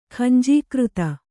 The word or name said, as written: ಖಂಜೀಕೃತ